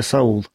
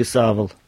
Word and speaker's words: Савл: Так его звали Саул. Ну, или Савл чаще всего у нас. Так это в связи с апостолом Павлом имя произносится.